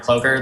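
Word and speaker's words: Ploeger: My name is Connor Plover. That's spelled P as in Paul, L-O-E-G-E-R.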